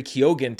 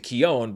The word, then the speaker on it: Keoghan: So I started saying Barry Keown, but then I found a more recent interview where he says, actually, no, it's Barry Keoghan. I just say it differently than everyone else in Ireland. So I had corrected my what I thought was my mispronunciation of Barry Keoghan to Keown, but now it's back to Keoghan.